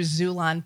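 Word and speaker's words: Xulon: It's also available through Zulon Press, which is spelled X-U-L-A-N.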